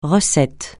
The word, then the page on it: recette: francais